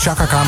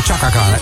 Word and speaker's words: Chaka Khan: Dat je niet zegt Chaka Khan, maar Chaka Khan.